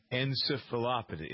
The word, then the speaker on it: encephalopathy: Dr. Sanjay Gupta has taken a look at deterioration problems of NFL players and the chronic, what they call traumatic encephalopathy. Is that how you pronounce it? – Encephalopathy, yes. Encephalopathy.